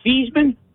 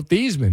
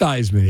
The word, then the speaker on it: Theismann: Joe Theismann, Drew Pearson. – You pronounced it right. Joe Theismann is not Theismann. It's Theismann.